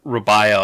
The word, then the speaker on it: Rabia: They pronounce it Rabia on the podcast, so I guess that's the way it's supposed to be said.